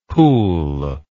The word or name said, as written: Peul